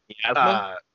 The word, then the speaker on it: miasma: Like I was like, oh man, like he's got these big how do you pronounce it? Masai arms. That's that purple gas. – Miasma. – Miasma.